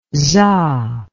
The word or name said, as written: za